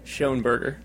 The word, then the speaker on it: Schonberger: Schoenberger.